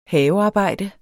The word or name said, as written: havearbejde